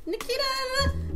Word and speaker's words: Naketa: And sometimes I can't even say my name right because I'm like so excited and it comes off like Naketa, and it didn't sound right at all.